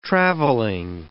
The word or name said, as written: traveling